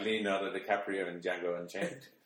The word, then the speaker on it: Django: Yeah, I know, but you pronounce it Django.